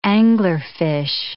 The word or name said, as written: anglerfish